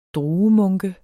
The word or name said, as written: druemunke